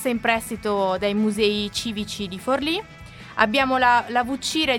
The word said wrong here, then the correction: Vucceria: di Renato Guttuso, che è proprietà dell'Università di Palermo. Avrò sicuramente sbagliato. – Vucceria! – Ah, la Vucceria, non vedo l'accento, ragazzi.